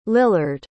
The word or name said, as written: lillard